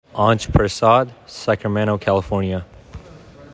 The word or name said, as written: Aansh Prasad